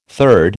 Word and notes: third: American English

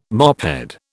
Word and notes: mophead: British English

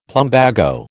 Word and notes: plumbago: American English

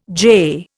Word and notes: jay: Indian

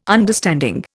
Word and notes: understanding: Indian

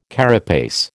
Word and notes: carapace: American English

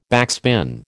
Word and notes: backspin: American English

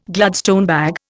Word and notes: Gladstone bag: Indian

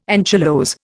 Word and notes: anchylose: Indian

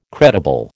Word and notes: credible: American English

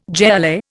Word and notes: jai alai: Indian

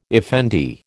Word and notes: effendi: American English

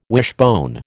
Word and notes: wishbone: American English

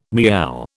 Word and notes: meow: British English

